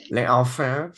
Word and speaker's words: L'Enfer: And I think you pronounce it L'Enfer. Is that how you pronounce that? – L'Enfer, maybe? – L'Enfer. Yeah, I think you're right. That sounds more like a proper French pronunciation. L'Enfer.